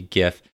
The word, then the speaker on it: GIF: I just like to send, for your sake, I will use the hard G, GIF. I like to send GIFs.